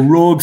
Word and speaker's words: Rogue: and he is also the performance coach of Rogue Esports, and especially the League of Legends. – It's pronounced Rogue. – Oh, Rogue. Sorry. – That's so French.